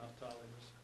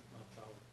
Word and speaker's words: Naphtali: Naphtali's holy calling. – Yeah, it's, here it is. It's Naphtali. – Yeah, Naphtali. – But it's the syllable, it's all, it's what we've been saying, it's just Naphtali.